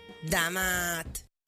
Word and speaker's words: Da Mat: Si scrive Da Mat, si pronuncia Da Mat.